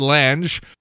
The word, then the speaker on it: Lange: Ted Lange, Isaac the Bartender on the Love Boat, 75.